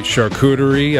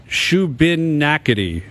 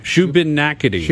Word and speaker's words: Shubenacadie: No, the town is actually pronounced Schubert Nakati. Schubert Nakati.